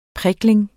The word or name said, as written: prikling